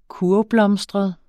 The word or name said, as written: kurvblomstret